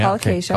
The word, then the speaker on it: colocasia: Yeah, yeah colocasia.